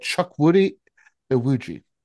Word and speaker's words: Chukwudi Iwuji: But Chukwudi Iwuji, I believe is how you might pronounce that.